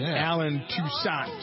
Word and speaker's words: Toussaint: Garth Brooks, Leon Russell, and Alan Toussaint. Is that how you pronounce his name? An R&B Alan Toussaint, guy? – Yeah.